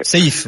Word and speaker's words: Seif: Comment il se prononce ton nom, pardon? – Bonsoir. – S-E-I-F, en fait, c'est Sefedin. – Sefedin. – Épée de la religion dans la religion musulmane. – Sefedin, donc. – Saïf, Saïf, voilà, c'est ma question.